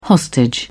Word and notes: hostage: British English

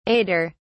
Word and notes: aider: British English

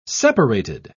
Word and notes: separated: British English

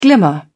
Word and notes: glimmer: British English

